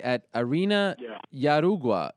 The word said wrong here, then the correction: Jaraguá: How do I say that? – Jaraguá in Jaraguá do Sul, Brazil.